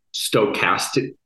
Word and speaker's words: stochasticity: Where I wrote my PhD thesis on how stochasticity, mm-hmm.